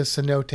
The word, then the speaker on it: cenote: And if you see the word, you may not pronounce it right, because it's spelled C-E-N-O-T-E-S. – It's like cenotes. – Cenotes? – Cenotes? – Is it key? Is it canotes? I don't know. It's cenote. All right? It's a cenote.